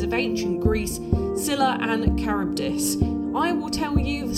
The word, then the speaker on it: Charybdis: It's pronounced Scylla, much like Scylla Black, but spelt S-C-Y-L-L-A, and Charybdis is kind of pronounced slightly like Cardi B, but also like the name Charis, but it's C-H-A-R-Y-B-D-I-S.